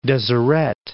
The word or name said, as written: Deseret